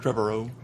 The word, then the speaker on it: Trevorrow: How do you say his last name? Trevorrow, who did Jurassic World. – Trevorrow, isn't it? – Trevorrow. Yeah.